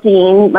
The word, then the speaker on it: Deen: And I have a website, uh, Michelle Deen. My name is spelled with two E's, D-E-E-N, uh, Michelle double L.